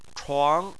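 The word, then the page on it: chuang: tone 2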